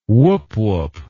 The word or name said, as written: woop woop